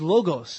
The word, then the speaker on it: logos: In the Greek, which is what the book was written in originally, for all practical purposes, the word Word is logos in the Greek, L-O-G-O-S, or logos, depending on how you want to pronounce it. Logos, logos.